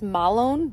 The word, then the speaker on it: Mahlon: So Ruth, she married Malone, and I believe in Hebrew it's pronounced Malone, but you know, I'm not Hebrew, so we're just going to say Malone. I like the name Malone. It's really cute. And it's actually spelled M-A-H-L-O-N.